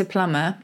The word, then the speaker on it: plumber: They sneaked a B into plumber, but we didn't pronounce it because sound and spelling had long ago divorced.